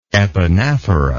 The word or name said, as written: epanaphoral